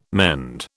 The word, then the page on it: mend: British English